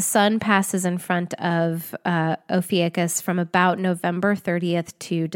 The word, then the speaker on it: Ophiuchus: And if any two astrologers cannot agree on the story of this sign and its place and its relevance within astrology, neither can we agree on the pronunciation. It's sometimes described as Ophiuchus, Ophiuchus, Ophiuchus, Ophiuchus, Ophiuchus. So it's spelled O-P-H-I-U-C-H-U-S. Given my mood, my pronunciation changes too. So today, maybe it's going to be Ophiuchus.